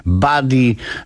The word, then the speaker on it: Buddy: To był Grów Kojat, grupa z Trójmiasta i utwór Funkius Buddy, Buddy, bo w zasadzie tak to się wymawia.